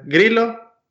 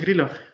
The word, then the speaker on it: Grillo: ¿Se dice grillo o grillo, como tipo latino? Digo, mirá, es italiano, se pronuncia las dos L, se pronuncia una, cosa que espero que algún día vos también, algún día en vez de decirme grillo, me digas grillo.